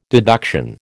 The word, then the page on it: deduction: American English